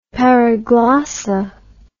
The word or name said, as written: paraglossa